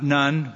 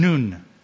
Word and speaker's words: Nun: Remember, not Nun. We don't want to get in trouble with our Catholic friends. So we are giving it the Hebrew pronunciation, Nun.